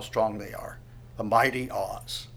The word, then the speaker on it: Oz: It's actually probably pronounced in the Hebrew Oz, it's the word O-Z, and it's a word for mighty.